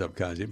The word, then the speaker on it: Abkhazia: Oh, see, am I saying it wrong? – You said wrong. I said it wrong. – No, my joke. I think it is Abkhazia, but good for them.